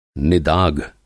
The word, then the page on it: निदाघ: Hindi